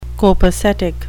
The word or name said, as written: copacetic